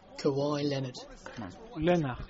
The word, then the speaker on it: Kawhi Leonard: Espera, a ver cómo es Kawhi Leonard. – Kawhi Leonard. – Está bien pronunciado.